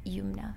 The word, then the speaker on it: Youmna: And for my personal Instagram and Twitter, it's at C Youmna, which is my name.